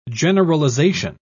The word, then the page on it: generalisation: British English